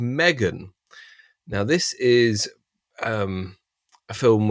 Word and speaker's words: M3GAN: So this is Megan, stylized to be M3GAN. It's pronounced Megan.